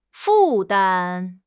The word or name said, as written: 负担